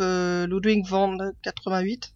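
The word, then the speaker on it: Ludwig von 88: Et euh, l'auteur, bah, c'est Karim Berouka, qui était d'ailleurs le, chanteur du groupe Ludwig van 88. Je ne sais pas si je le dis bien. Ouais, ouais, très bien.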